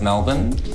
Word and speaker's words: Melbourne: And the pronunciation of Melbourne? – Melbourne. – Of course. – Melbourne. – Nailed it. Yay. Round of applause. Yeah. We like to throw away our vowels in Australia.